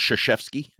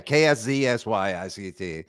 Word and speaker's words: Krzyzewski: You know, it's actually pronounced Krzyzewski. It's weird. – The funny thing is, yeah, Krzyzewski. Krzyzewski, exactly.